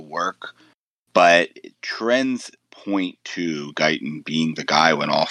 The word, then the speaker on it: Guyton: I'm assuming Guyton, I believe. – Guyton? Yeah, Guyton.